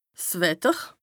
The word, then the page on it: 's Wetter: Bas-Rhin; Strasbourg